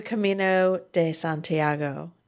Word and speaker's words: Camino de Santiago: And to Camino de Santiago. Did I say that properly? – Yeah, you did. Yes.